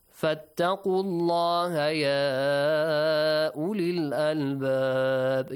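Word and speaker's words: الله: فَاتَّقُوا اللَّهَ. Allah is pronounced with an A sound.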